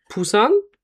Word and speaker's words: Busan: Und die ersten Flüge gingen nach Busan, richtig ausgesprochen? – Ja, das wird zwar mit B geschrieben, aber wird P ausgesprochen.